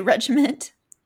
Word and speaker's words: regimen: I think you said my new regiment, but it's actually my new regimen. It's not regiment.